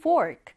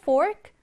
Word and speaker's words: fork: 우리말로는 포크라고 하지만 영어로 읽으실 땐 fork 이렇게 F 발음을 살려주셔야 합니다. 왜냐하면 오른쪽을 보시면 pork라고 하는 돼지고기라고 하는 단어와 헷갈릴 수 있거든요. 그래서 pork, 돼지고기, 그리고 fork, 포크 이렇게 구분해서 알아두시면 됩니다. 자, fork 한번 써볼게요. F-O-R-K 이렇게 쓰고요.